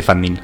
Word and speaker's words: Fundeen: Decimos f u n d e n e, para que, para que la gente lo pueda buscar, pero sí, sí, se dice Fundin.